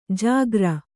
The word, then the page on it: ಜಾಗ್ರ: jāgra